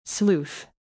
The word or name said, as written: sleuth